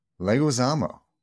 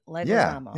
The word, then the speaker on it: Leguizamo: Help me out. – Leguizamo. – Leguizamo. Did I say it right? – Leguizamo. Yeah, yeah I think so.